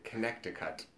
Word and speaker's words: Connecticut: Connecticut? – Yeah, Connecticut. Did I say it right? There's a C that you don't say. yeah. – Connecticut.